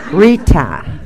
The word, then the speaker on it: Rita: Rita, with a T. I'm from the East Coast, so I usually put a D there and finally got tired of it,